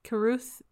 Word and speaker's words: Carruth: I think I'm pronouncing his right last name right. Carruth. It's C-A-R-R-U-T-H.